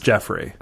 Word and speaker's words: Geoffrey: Well, it's spelled Geoffrey, but it's Jeffrey.